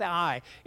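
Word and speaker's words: Ai: The same thing with I. It's spelled A-I, but it's a diphthong that's just to be pronounced I.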